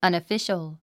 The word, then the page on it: unofficial: ʌ̀nəfíʃəl; アナフィシャル